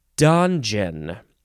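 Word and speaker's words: donjon: I think it's, so it looks like it's Dungeon, which is, well, you know, let's just read it. It is spelled Dongjon.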